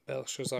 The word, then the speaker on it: Belshazzar: And then it's Bella Caesar, or whoever that is. – Belshazzar, comes, yeah. – Yeah, comes in. – There you go.